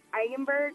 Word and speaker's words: Eigenberg: And she got a shout out from David Eigenberg, I think you pronounce it. – Yeah, that's right. Yeah, you got it.